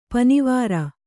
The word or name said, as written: ಪನಿವಾರ